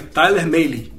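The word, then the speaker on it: Mahle: É Tyler Maley.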